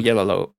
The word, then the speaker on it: Oyelowo: David Oyelowo? Why can't I never get his name right? – We've got Common. – Oyelowo. Oh, God, you got me.